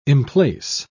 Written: emplace

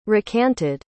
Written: recanted

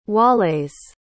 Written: Waleis

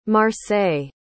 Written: marseilles

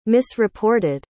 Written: misreported

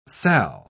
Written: salle

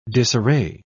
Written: disarray

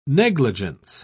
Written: negligence

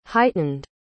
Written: heightened

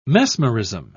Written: mesmerism

